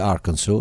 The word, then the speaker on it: Arkansas: Nonostante sia figlio di un mezzadro, nasce nel 1946 a Fort City in Arkansas, perché Arkansas si dice Arkansas, lo sapevi, Arkansas? – Sapevo, ma sì, non sapevo pronunciarlo.